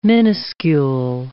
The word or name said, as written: miniscule